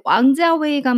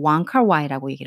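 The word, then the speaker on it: 왕칼와이 (王家衛): 근데 왕자웨이 감독을 공식적인 자료, 뭐 위키피디아 같은 곳 아니면 유튜브 어느 외국인들이 말할 땐 전부 왕칼와이라고 얘기를 하죠. 그게 어, 이분의 광동어 발음으로 그분이 왕자웨이 감독께서 이제 세상에 인터내셔널하게 소개를 하고 말씀을 하고 있기 때문이에요. 근데 그분 입장에서는 그게 모국어니까 그렇게 말하는 게 맞겠죠.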